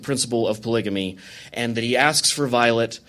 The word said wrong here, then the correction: Vilate: his wife – I just pronounced her name wrong. I'm sorry. Violet? Vilate? Vilate.